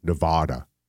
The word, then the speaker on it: Nevada: They say Nevada. – Spelled like Nevada? – Yes. Yep.